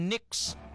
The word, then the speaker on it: Knicks: So gintawag na siya nga Knicks. Silent K.